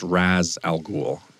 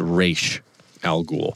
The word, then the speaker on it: Ra's al Ghul: If you watch the Christopher Nolan trilogy, his name is pronounced Ra's al Ghul. If you watch the Arrowverse and most of the rest of DC Universe stuff, it's Ra's al Ghul.